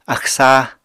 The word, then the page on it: axw sáa: apatani